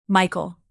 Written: michael

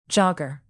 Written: jogger

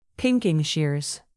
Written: pinking-shears

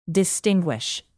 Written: distinguish

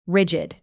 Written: rigid

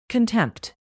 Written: contempt

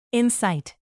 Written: incite

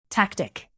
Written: tactic

